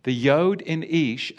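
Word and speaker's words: Ish (איש): And wife is spelt Aleph Sheen Hey Isha.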